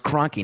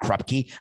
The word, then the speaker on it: Kroenke: No, it's pronounced Kroenke, not Krupke.